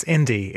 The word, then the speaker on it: Ind.ie: Uh, it's Indie. It's just Indie. When we got the domain, we thought we were being very clever. At least I did, because um, it just spells Indie, but with a dot in it.